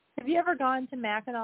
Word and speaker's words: Mackinac: It's spelled Mackinac, but they say Mackinac,